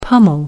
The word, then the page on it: pommel: American English